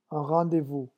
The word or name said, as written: un rendez vous